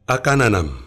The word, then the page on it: akananam: Efik